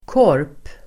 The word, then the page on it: korp: [kår:p]